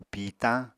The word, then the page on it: pìitañ: apatani